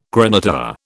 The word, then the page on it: graniteware: British English